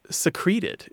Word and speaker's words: secrete: but isn't pronounced like secret in any of its forms, including when it's conjugated or inflected. – Mm-hmm. – So it is always secreted. It's secrete. You secrete the letter in the floorboards.